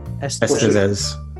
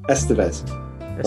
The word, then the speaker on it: Estevez: Do you pronounce it Estevez or Estevez? – Estevez. – Estevez. Right. Okay.